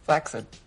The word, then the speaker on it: flaccid: Flaccid. It's actually pronounced flaccid. Not a lot of people know that.